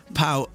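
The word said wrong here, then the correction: Pau: Although someone's been on saying it's pronounced Poe.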